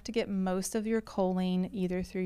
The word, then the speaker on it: choline: The topic today is choline. – Yes. – Did I say it right? – You did.